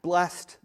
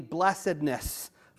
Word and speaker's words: blessedness: All right, but then when you see B-L-E-S-S-E-D, and it's used as part of something much longer, like a part of a noun or part of an adverb, you go with two syllables.